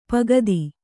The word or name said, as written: ಪುಗುತೆ